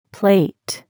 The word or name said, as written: plate